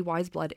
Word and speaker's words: Weyes: Her name's actually Natalie Maring, not Wiseblood, and it's spelt W-E-Y-E-S, mm-hmm. which a lot of people don't know how to pronounce it. When we were talking about doing the show, a lot were like, oh, is it Ways, Wise, whatever? – Yeah. – It is actually Wiseblood, and she named herself after a book.